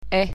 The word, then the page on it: e: latvian